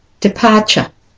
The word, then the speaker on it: departure: And the next word is departure. So the last syllable, T-U-R-E, that common ending, is always said as a schwa. So it becomes ch. So departure.